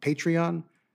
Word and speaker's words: Patreon: Patreon? – Yeah.